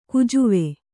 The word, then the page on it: ಕುಜುವೆ: kujuve